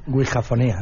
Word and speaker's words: Ouijafonías: Ouijofonías. Ouijo, no lo digo bien, ¿cómo se dice? – Ouijafonías. – Ouijafonías.